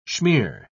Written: schmear

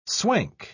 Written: swank

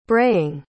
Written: braying

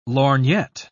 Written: lorgnette

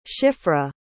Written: Shifra

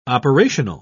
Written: operational

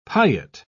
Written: piet

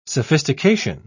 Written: sophistication

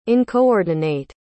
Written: uncoordinate

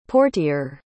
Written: portier

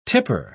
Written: tipper

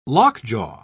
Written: lockjaw

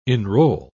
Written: enroll

